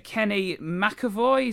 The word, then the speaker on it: Kenny McAvoy: That link with Kenny McAvoy, is that the correct pronunciation of his name? – Sounds about right.